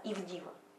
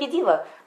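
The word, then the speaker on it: ИВДИВО: Потом через несколько дней все меняется, взошли, называется, и вышли в изначально вышестоящий дом изначально стоящего отца, ИВДИВА. У нас теперь называется ИВДИВА. Ну, звучит как ИДИВА. Владыка сказал, можно говорить ИДИВА, но мы понимаем, что это пишется изначальный дом, изначально вышестоящий дом изначально стоящего отца.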